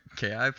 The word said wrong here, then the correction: Ki: points? Did I say that wrong? – They're key points.